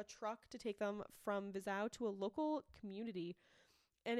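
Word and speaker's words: Vizhai: Nope, because this one I knew I said it wrong probably 18 times, and then I heard them say it in the video and went, wow, I was wrong. And it's spelled V-I-Z-H-A-A-H-A-Y, and it's Vizau.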